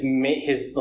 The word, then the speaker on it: Aech: Um, and actually, in the plot in the in the book, um, his his like his bro, his buddy H, yeah. or Ack. I think they, they pronounce it H, and it's spelled A E C H in the book.